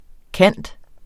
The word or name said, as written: cand.